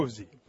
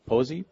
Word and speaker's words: Povse: Posey. – Posey, P-O-V-S-E.